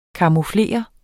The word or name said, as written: camouflere